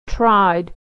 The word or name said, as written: tried